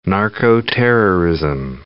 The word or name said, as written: narco-terrorism